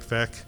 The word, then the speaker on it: Vec: It's a movie about this guy Mike Vec, rhymes with wreck, huge baseball guy.